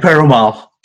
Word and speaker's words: Perumal: Sushee Perumal. – Perumal. It's like it reads, right? – It just rolls off your tongue.